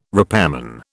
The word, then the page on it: repairman: British English